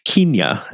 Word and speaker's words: Kenya: They are all out in Kenya, or Kenya, as I feel certain they would have pronounced it.